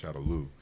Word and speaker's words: Shadaloo: Don't take me to the bank, but it's now pronounced Shadaloo.